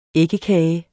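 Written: æggekage

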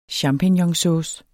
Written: champignonsauce

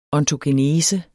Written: ontogenese

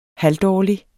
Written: halvdårlig

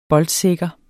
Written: boldsikker

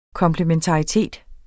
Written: komplementaritet

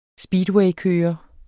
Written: speedwaykører

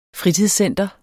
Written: fritidscenter

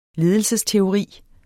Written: ledelsesteori

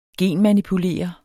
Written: genmanipulere

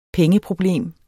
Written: pengeproblem

